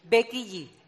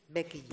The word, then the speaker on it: Becky G: La última creo que había sido, no sé si lo pronuncio bien, la Becky G. ¿Lo he dicho bien? – Becky G. – Esa. – Becky G.